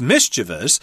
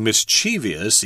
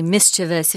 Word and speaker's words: mischievous: The correct form is mischievous, but you will hear a lot of people saying mischievous, even on TV and TV programs, they say it all the time.